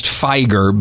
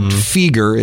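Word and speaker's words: Fieger: I've heard it pronounced Feiger, but Feger is how I've always pronounced it.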